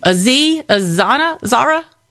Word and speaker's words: Aziz Ansari: Aziz Ansari.